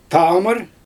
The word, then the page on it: taamɯr: Xyzyl